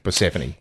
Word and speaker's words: Persephone: Persephone, is it? – Yeah, Persephone. Yeah. – See, there I don't even know the embassy. – The emphasis on the emphasis. Right syllable.